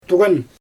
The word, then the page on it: tuɣan: Xyzyl